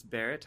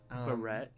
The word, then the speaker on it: Barret: Actually, my last name is pronounced Barret. – Barret. – It's Nicolas Barret. – Nicolas! – Like the fancy hat the French people wear.